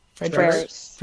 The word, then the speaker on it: Frerichs: Uh, another person has left Bowerman Track Club, Courtney Frerichs. Have I said that right? – Fredericks.